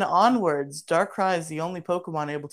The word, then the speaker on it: Darkrai: Darkrai! – You didn't say it right. – Darkrai! – See, Gingy got it.